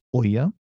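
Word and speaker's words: ʻo ia: which is normally preceded by Okina O the Okina O with ia is pronounced oya.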